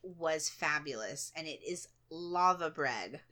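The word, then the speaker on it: laver: And it's spelled L-A-V-A-R. – Yeah. – I think, but you guys pronounce it lava, don't you? Because I didn't realize there was an lava. R on the end.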